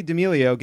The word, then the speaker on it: D'Amelio: What did TikTok star Charlie D. Emilio get named after? Did I say that right? – What? D'Amelio.